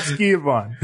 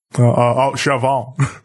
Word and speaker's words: Schiavone: It happened with Tony Schiavone, where I was like, yo, How do you actually pronounce his last name? – It's not Skiavon. – Because I had Skiavon, and I forgot what the other one was that Jericho was also using. – Chavon.